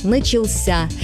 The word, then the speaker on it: начался: Посмотрите, в неопределенной форме мы говорим «начаться». Ударение падает на второй слог. А вот в форме прошедшего времени мужского рода ударение уходит в конец – «начался».